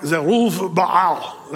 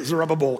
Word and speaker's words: Zerubbabel: Now, if you want to say it like a Hebrew, it's more like Zerubbaal. That's how you say his name. But we say Zerubbabel because it's fun.